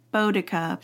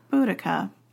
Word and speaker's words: Boudica: But one person was like, it's like bow and arrow, Boudica. But sure. – Then, like, no one says that. It was all like Boudica.